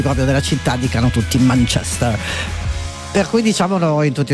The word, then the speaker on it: Manchester: Sì, che poi c'è sempre questa cosa, dove mettere l'accento quando si dice Manchester? C'è chi mi dice Manchester, si dice Manchester, c'è invece chi dice Manchester. Io ho sentito Paul McCartney, che peraltro è di Liverpool, dire Manchester. Ho sentito anche David Beckham dire Manchester.